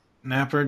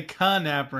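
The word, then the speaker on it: Knapper: Napper Dragon? Okay. I was going to say... – You're going to say Bone Raper? – No, I was going to say Ka-Napper and pronounce the K, but...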